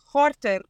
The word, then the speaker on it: Gorter: Unless you want to spell it or pronounce it the Dutch way, and then it's Gorter.